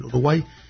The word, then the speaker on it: Uruguay: Y en concreto lo manda Gustavo desde Montevideo, Uruguay. Uruguay, he pronunciado mal, Uruguay.